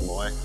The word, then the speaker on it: Moy: My name is Steve Moy. My last name. – Moy. – Moy. – M-O-Y. Rhymes with my first name.